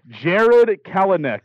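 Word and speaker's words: Jarred Kelenic: Jared Kelenic.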